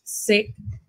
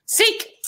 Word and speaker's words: Sikh: She plays Harriet Carr, a young Scottish Sikh. Is that how you pronounce it? – Sikh! – Sikh! A young Scottish Sikh.